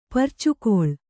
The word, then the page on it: ಪುರ್ಚುಗೊಳ್: purcugoḷ